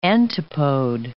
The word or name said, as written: antipode